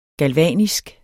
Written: galvanisk

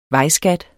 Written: vejskat

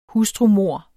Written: hustrumord

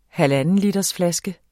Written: halvandenlitersflaske

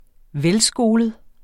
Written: velskolet

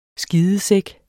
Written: skidesæk

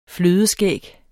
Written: flødeskæg

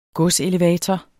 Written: godselevator

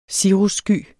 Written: cirrussky

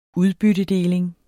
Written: udbyttedeling

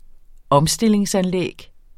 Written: omstille

